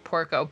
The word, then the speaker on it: Porco: So this is the story of Joan and Peter Porco, I believe is how you pronounce their last name. Porco, P-O-R-C-O. – That sounds like Porco.